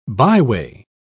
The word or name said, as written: byway